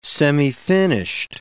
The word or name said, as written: semifinished